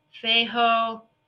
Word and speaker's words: Fehu: It's called Feho. F-E-H-U is how it's spelled, but it's pronounced Feho.